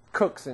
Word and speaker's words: Coke: Cook's Institutes, for the English amongst you. They pronounce it Cook, because he's a great chef rather than a lawyer, but that's a different point.